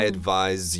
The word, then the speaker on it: advise: Right, so this S, even though it's an S, it sounds more like a Z. I advise you to do this.